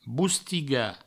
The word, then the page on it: bostigar: boustiga